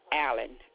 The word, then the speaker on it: Allen: P-A-T-R-I-C-I-A, Patricia. My last name is Allen, A-L-L-E-N.